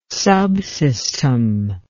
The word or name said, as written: subsystem